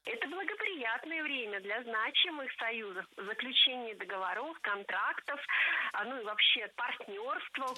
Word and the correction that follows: договор: Не договоров, а договоров. Вспоминаем тему выпуска. Существительные с неподвижным ударением на основе. И это значит, что ударение в слове «договор» не двигается, всегда остается на третьем слоге. Договоры, договоров, договорами.